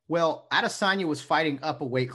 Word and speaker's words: Adesanya: How do you pronounce the name? Asanya? – Yeah, Adesanya. Adesanya. Israel Adesanya.